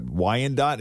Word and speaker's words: Wyandotte: A neighborhood in the Michigan city of, is that Wyandotte? Is that how you pronounce it? – Yep.